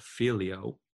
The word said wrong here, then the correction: phileo: So every time I say that in this podcast, please correct it to phileo.